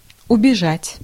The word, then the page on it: убежать: /ʊbʲɪˈʐatʲ/; ubežat